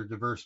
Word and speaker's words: divers: And Matthew and Mark both talk about earthquakes in various or diverse places. It's pronounced divers in the Old King James, but it means diverse, all over the place, basically.